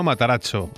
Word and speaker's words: Matarazzo: Matarazzo.